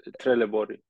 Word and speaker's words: Trelleborg: Is that how you say it? Trelleborg. – Yeah, Trelleborg. Yeah.